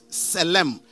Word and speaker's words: tselem: And the Hebrew word for the image is tselem. T-S-E-L-E-M.